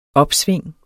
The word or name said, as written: OPSVINGENES